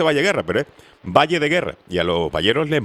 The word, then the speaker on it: Valle de Guerra: No se dice Valle Guerra, se dice Valle de Guerra, que a los valleros les molesta.